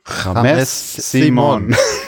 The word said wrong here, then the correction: James Simon: James Simon.